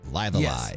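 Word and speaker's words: Live A Live: That's how we decided we are pronouncing that, right? Live Alive. – Yes, Live Alive.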